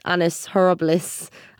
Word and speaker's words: Annus Horribilis: I mean, I was going to say, how do you think this year compares to 1992's Annus Horribilis? Am I saying that right? – Yeah.